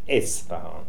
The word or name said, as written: Isfahan